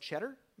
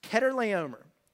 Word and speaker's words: Chedorlaomer: Everybody looks at this name and it's like, cheddar? No, it's not that. This Chedorlaomer, I looked it up. That's how you say it.